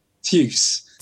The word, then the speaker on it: Tyus: I have no idea how to pronounce that. – Chuice. – His name is T-Y-U-S. Tyus? Tyus. – Chuice. – Chuice. – T-Y, spell it again. – T-Y-U-S. – T-Y-S. – Tuce. – Tuce. Tuce.